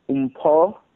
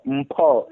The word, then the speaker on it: Umpa: My name is pronounced Umpa Quaho. Quaho. Umpa Quaho? – Umpa. Umpa. Emphasize. Emphasize the O. Umpa.